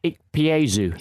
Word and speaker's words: Ikpeazu: Uchi Ikpeazu.